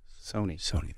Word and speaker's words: Sony: Sonny Michelle? Sony. – Think of like PlayStation.